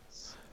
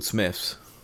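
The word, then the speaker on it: Smyths: Is that shop called Smythe's? – Oh, I thought it was called Smith's. – I also thought it was called Smith's. – That's just how I've been pronouncing it for ages.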